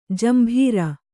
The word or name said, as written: ಜಂಬೂರಿ